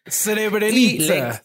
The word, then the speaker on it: Srebrenica: Milosevic lo que hace es lo que les contaba, las retira pero se las da a la República de Srebrenica, perdón si lo pronuncio mal. – Srebrenica, según, según investigué.